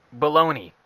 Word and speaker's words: bologna: Baloney. It sounds Bologna. Nowhere near the way it's spelled.